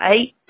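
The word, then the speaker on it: ate: I would pronounce that eight. Sounds like a number eight, that.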